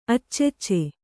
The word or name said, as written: ಅಚ್ಚಚ್ಚೆ